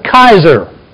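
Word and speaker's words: Kaiser (Καῖσαρ): Well, that's how you say it in English. In Greek, you pronounce it Kaiser.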